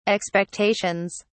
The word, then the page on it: expectations: British English